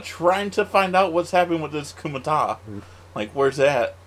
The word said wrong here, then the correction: Kumite: Did I say it wrong? – Yeah. You're going to keep saying it wrong, so. – Kumite? – Kumite.